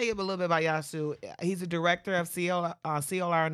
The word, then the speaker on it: Eyaassu: His name is Yasu, and Yasu is spelled E-Y-A-A-S-S-U, but the best way to pronounce his name is drop the E. So Yasu.